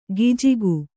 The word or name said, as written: ಗಿಜಿಗು